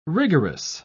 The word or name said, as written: rigorous